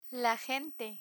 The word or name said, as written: la gente